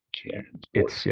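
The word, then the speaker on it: Ciarán Bourke: And I probably mispronounced Ciaran Bourque's name. It's Ciaran Bourque.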